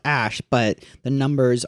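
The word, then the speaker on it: Æ: It's like Ash. – Well, like part of it is Ash. Like the AE, that's Ash.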